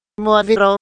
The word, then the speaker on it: Moavero: Moavero, si legge così, con l'accento sulla A,